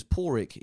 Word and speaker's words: Páraic: My name is Páraic.